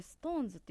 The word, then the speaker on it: SixTONES: はい、これストーンズって読むんですね。 – そうなんですよ。 – ちょっと綴りを教えてあげてください。 – SIXTONES 読めますか？大丈夫です。 – そう、あのシックストーンズと書いて、ね、ストーンズって読むんですよね。